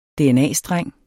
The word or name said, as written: dna-streng